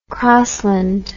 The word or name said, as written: crosland